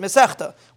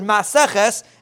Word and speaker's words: Masechta: It, there's a big discussion how to pronounce the word Miseches. If you look at the beginning of your Gemara, usually it says here maybe it doesn't, but a lot of times it says miseches without an aleph. Here use we we we pronounce it misechta. Where did we get it from? We got it from this Gemara. The Gemara over here uses the term misechta.